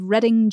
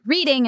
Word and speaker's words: Reading: And that ended up with Oscar jailed for 2 years, which he immortalized in his poem, The Ballad of Reading Jail. And then we immortalized ourselves in Corrections Corner because I pronounced it reading instead of Reading.